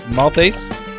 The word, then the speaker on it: Maltais: And this week we have a special guest, and that's, I know I'm going to say your name wrong again, it's Jevin Maltais. – Yeah, that's pretty good, man. It's French-Canadian, so it's Maltais, or Maltais, basically silent S.